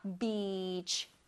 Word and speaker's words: beach: 이건 길게 발음해 줄 거예요. beach, beach. 끝에는 ch, ch, ch 소리 나게.